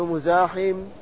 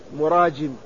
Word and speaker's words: مراجم: وكقول يحيى بن معين رحمه الله في ترجمة العوام بن مزاحم قال: بزاي ومهملة، هكذا نطقه يحيى وصوابه محمد وصوابه العوام بن مراجم، قال: براء وجيم.